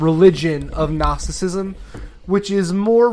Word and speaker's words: Gnosticism: I think there are two ways we can categorize Gnosticism. There's like the modern day... – Are you sure you're pronouncing it right? There sounds no way that that's how it's... – Gnosticism, yes.